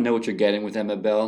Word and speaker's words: Emma Bell: Emma Bell.